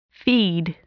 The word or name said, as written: feed